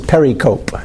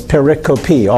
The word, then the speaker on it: pericope: P-E-R-I-C-O-P-E. Sometimes people mispronounce it pericope. But it's pericope. All four syllables are pronounced.